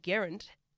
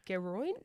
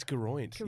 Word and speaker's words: Geraint: Well, you know, the other really prominent one, Geraint Thomas, we call him Geraint. Technically, it's like Geroint. – It's Geroint.